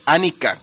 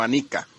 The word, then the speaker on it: Anika: Nada más si quiero corregir a Calaca porque es Anika, con acento en la primera, no Anika, por favor.